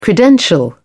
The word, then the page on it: credential: British English